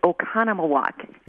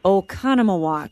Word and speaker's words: Oconomowoc: get that pronunciation right. It's Oconomowoc. – That's right, and here it is from your website. – Oconomowoc.